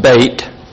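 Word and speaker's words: Beit (בית): It's actually pronounced Beit in the Hebrew.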